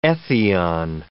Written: ethion

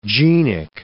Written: genic

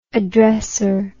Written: addressor

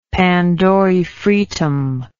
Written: pandorae fretum

